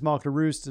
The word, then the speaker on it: Mark Leruste: Well done for pronouncing my name. I'm very impressed. Yeah, my name is Mark Leruste, and I'm half French, half English, and nobody's perfect, but that's why I have an unpronounceable name.